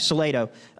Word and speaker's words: Salado: It's at a beautiful camp in Salado. I said that right. Okay, Salado.